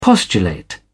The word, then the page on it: postulate: British English